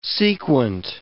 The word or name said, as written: sequent